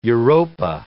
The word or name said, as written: Europa